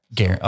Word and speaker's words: Gearen: Jared Gearen? – Yep. – How do you spell Gearen? – G-E-A-R-E-N. – Okay. Gearen.